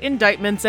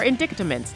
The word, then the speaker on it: indictment: Everyone is talking about indictments, and we will too. – Like, why is there a C in that word? And why isn't it pronounced indictments? – Or indictaments, like a Hanna-Barbera character.